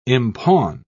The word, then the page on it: impawn: British English